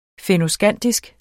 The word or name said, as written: fennoskandisk